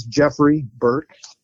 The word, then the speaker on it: Geoffrey Burke: My name is Geoffrey Burke. Geoffrey is spelled the old-fashioned way. It's G-E-O-F-F-R-E-Y. My last name Burke is B-U-R-K-E.